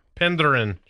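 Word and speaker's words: Penderyn: Penderin. Selt. – Kelt. – I think you actually got it right.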